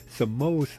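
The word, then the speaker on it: Samoht: Samo so bad, the letter T on the end of his name is silent.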